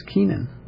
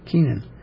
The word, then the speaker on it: Kenan: Right, although there may be alternate pronunciation even of that name in English. The first Kainan, in some translations, who had the same name but was an earlier person of that name, in some translations, he's Kenan. Kenan. In English, so you know, there's a lot of ways to pronounce names even in English, let alone comparing with Chinese and so on.